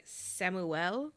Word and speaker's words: Samuel: While she's trying to conduct this interview, her husband, Samuel, I guess the French pronunciation is more like Samuel.